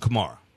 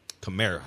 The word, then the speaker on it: Kamara: Uh, uh Saquon Barkley, Zeke Elliott, Christian McCaffrey, and Alvin Kamara. Kamara, however you want to describe it. Both now, are correct, actually.